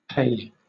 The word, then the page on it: pe: British English